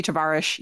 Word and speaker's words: Tavares: Y-U-R-I, Yuri Tavares. Yeah. Oh, it's Portuguese, so it'd be Tavares.